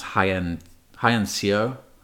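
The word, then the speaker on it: Hyein Seo: And there's the Korean designer, I'm probably going to butcher this, but I think it's pronounced high end CEO. Like HYEINSEO.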